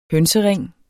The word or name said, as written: hønsering